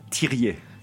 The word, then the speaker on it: Thiriez: So my name is Daniel Thiriez.